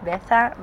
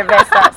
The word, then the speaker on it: Veza: Actually, Veza, I use it here because my name, as my parents pronounce it, is Veza, but it's easier, Veza. So I'm now Veza.